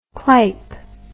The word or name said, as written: clype